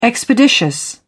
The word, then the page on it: expeditious: British English